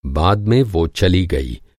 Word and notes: बाद: بعد

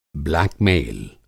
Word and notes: ब्लीडिंग: Hindi

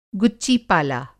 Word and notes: गुच्चीपाला: गुच्ची-पाला

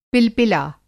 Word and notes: पिलपिला: Hindi

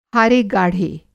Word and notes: हारेगाढ़े: Hindi

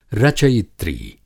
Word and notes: रचयित्री: Hindi

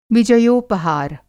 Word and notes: विजयोपहार: Hindi

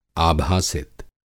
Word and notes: आभासित: Hindi

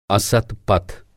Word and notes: असत्-पथ: Hindi

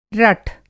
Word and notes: रठ: Hindi

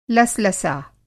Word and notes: लसलसा: Hindi

